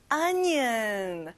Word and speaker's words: onion: 양파, onion. 앞에 O가 아니라 A예요. Onion.